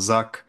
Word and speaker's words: Zach: Moje imię, jakby ktoś jeszcze miał jakieś pytania, można wymawiać na wiele różnych sposobów. Ja zwykle wymawiam Zak, ale jak ludzie widzą CH i mówią H, to też Zach.